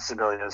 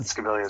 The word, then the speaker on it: Scibilia: It's a soft C sometimes if you want. Scibilia is fine, but Scibilia is great too.